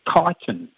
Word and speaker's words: Chiton: And then, even then, so the correct pronunciation is chitin, chitin whey. – And it's spelled C-H-I-T-O-N.